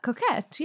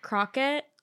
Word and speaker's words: coquette: Is that how you say that word? – Coquette, yeah. – It's coquette. I've always looked at it for the past couple months that it's been a trend, thinking to myself, how do you say that? – Well, today's the fucking day, bitch. – I think I was thinking like crock pot, like croquette.